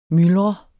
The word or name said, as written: myldre